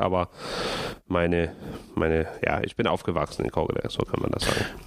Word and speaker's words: Kokkedal: Du kommst aus Kokkedal, habe ich das richtig ausgesprochen? – Ja, Kokkedal.